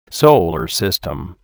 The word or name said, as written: Solar System